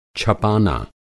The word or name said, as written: छपाना